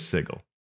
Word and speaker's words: Sigil: And it explains that to mispronounce it is to give it a J sound, and it's spelled S I J I L to make that point. So that would say that it is Sigil.